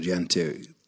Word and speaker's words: Jante: In Denmark, you have the law of the Jante, or Jante, I've pronounced that correctly. – Yeah.